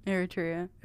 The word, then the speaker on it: Eritrea: Eritrea.